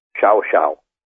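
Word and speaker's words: Llao Llao: Or at a place just outside of Bariloche, it's spelled LLAO-LLAO, and in Argentine Spanish, it's pronounced Chao Chao.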